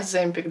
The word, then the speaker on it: Оземпик: Про земпик, да. Во-первых, я узнала, что ударение на Е, а не на И в конце, что тоже уже открытие, как минимум.